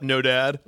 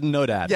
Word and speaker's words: Nodad: But his last name is No Dad. – Yeah, yeah. Jeremy No Dad. – Jeremy No Dad. – And it's spelled N-O-D-A-D, but he also coincidentally has no father.